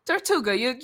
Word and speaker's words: Tortuga: Tortuga, you said it right.